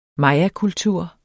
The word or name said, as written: mayakultur